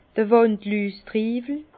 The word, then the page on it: d'r Wandlüstriiwel: Bas-Rhin; Herrlisheim